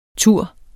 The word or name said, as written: ture